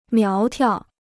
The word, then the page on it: 苗条: miáo tiao